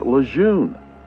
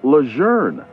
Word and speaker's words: Lejeune: Most people tend to call it Camp Lejeune, but the pronunciation of the general's name is actually Lejeune instead of Lejeune.